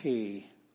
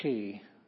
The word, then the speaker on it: chi: Chi, this one you gotta scrape the back of your throat a little bit. Chi. Chi, as in Bach.